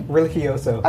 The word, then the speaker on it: Religioso: Religioso.